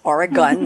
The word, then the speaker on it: Oregon: It's Oregon, not Oregon.